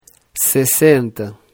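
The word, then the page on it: 60: Brazilian Portuguese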